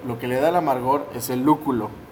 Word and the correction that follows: lúpulo: El lúculo. No, el, el, es el lúpulo. Saludos al pollo también. Saludos al pollo, este, no puede decir lúpulo. No puede pronunciar lúpulo, dice lúculo y pues muy callado.